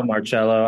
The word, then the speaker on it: Marcello: Is that the right way of pronouncing your surname, Mark? Hello, Marcello. I'm kind of easy on it. Usually the soft C, though.